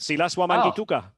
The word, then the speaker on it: Wamangituka: I've got a favourite player, and I'm not sure if I'm saying his name right. Silas Wamangituka. – Yeah, very good. Perfect. Silas Wamangituka. Yeah, perfect. Fantastic. Perfect pronunciation.